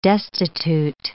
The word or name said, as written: destitute